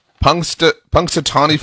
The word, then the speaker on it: Punxsutawney: But correct me if I'm wrong on the pronunciation here. Punxsutawney Phil? – Yeah, as the big overfed gopher up there is.